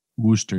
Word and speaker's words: Worcester: Worcester? – Worcester, yes.